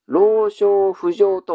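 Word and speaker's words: 老少不定: おじいさんの老人の老の字と少ないという漢字と不足するという漢字、この四字を組み合わせたらどう読みますか。老少不浄と読みますね。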